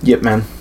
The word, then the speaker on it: Ip Man: I saw the first, is that how it's pronounced? Ip Man or Eep Yip Man? Yip Man. – Yip Man? – Yip Man. Yeah, okay. I mean, it's a Cantonese.